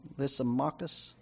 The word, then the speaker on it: Lysimachus: How do you say that? Lysimachus? That sounds good.